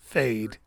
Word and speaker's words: Phade: King Shirt King Fade. That's right. And Fade is spelled P-H-A-D-E.